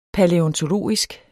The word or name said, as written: palæontologisk